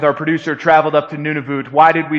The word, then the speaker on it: Nunavut: And I know some of you are thinking, isn't it none of it? Isn't it Nunavut? The answer is yes. I'm pronouncing it how they told me to pronounce it.